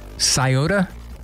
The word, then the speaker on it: Sciota: S-C-I-O-T-A. – Probably going to butcher it. Sciota? – Good job. – It is Sciota? Okay. – Well, I'm trusting the internet here. S-H-I-K-E-L-L-A-M-Y.